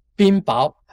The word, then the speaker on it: 雹: 啊，他可以求这降冰雹啊，这个冰雹啊，读作包吧，啊，冰雹啊啊，说冰雹，那降雹这奇怪了，这个降雹干什么？